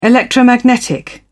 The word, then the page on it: electromagnetic: British English